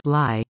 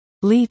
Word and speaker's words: Lee: He said he now uses Lee because too many people mispronounced Lee as Lai.